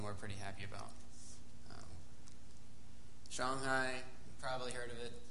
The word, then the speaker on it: Shanghai: But the A in Chinese, it's like you pronounce like ah. So Wuhan, it's like W-U-H-A-N, it's Wuhan. Shanghai, S-H-A. So that's just how they do it. They say Shanghai.